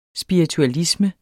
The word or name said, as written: spiritualisme